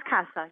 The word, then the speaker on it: CASA: Well, you're with a group that the acronym is CASA, or do you pronounce it CASA? – It's pronounced CASA, yes.